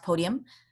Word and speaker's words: Podyum: It's spelled podyum.ca. And it's pronounced podium.